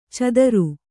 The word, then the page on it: ಛಾದರು: Kannada